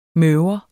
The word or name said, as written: møver